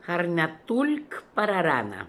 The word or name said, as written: Harnatoolkpararana